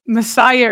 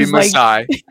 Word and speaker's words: Maasai: So he came and very kindly and politely said that it is the Maasai tribe. I don't know what we've been saying. – I think I said Messiah or something. – Maybe Maasai. And so it's Maasai.